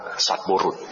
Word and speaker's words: สัตบุรุษ: สัตบุรุษสัตบุรุษอ่านว่าสัตบุรุษนะไม่